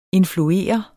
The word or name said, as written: influere